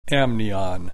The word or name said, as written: amnion